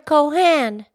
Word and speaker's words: Cohan: It's George M. Cohan.